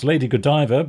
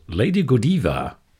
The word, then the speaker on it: Lady Godiva: We're going to play a track which we might pronounce Lady Godiva, but they pronounce Lady Godiva.